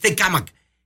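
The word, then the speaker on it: Tecámac: Estamos hablando que esto ocurrió en Tecamac, o en Tecamac, más bien, es la palabra correcta, la pronunciación es Tecamac.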